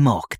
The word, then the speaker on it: Mok: So, Mr. Mock, let me just check that I have your name right. It's Ken Mock. Mock is spelt M-O-K, right? – Yes, Ken Mock, that's right.